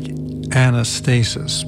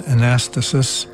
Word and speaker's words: Anastasis: It looks like Anastasis, but the actual pronunciation is Anastasis.